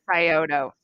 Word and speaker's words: Scioto: But some, I guess Scioto County, is that, am I pronouncing that right? Scioto County? – I think it's Scioto.